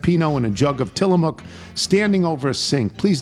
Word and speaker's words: Tillamook: Given your internet search, I'm surprised you know it's Tillamook. – Tillamook. Well, they don't tell you how to pronounce it. It just looked to me like Willamook.